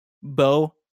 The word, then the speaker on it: Beau: That's spelled B-E-A-U James. Bo James.